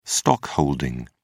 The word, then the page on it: stockholding: British English